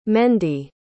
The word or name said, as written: Mende